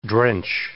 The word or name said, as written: drench